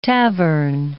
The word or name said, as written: tavern